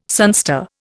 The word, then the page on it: sunstar: Indian